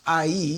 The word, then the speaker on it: Ai: And so this sets us up for now to try to take over IE, AI, spelled AI, but pronounced IE.